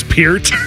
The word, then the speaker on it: Peart: And Neil Peart was the drummer of Rush for 40 years. It's Peart. – Oh yeah, right.